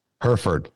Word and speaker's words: Hereford: Hereford, just like Hereford. Uh, it's her Ford truck.